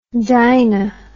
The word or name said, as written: dina